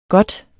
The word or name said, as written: godt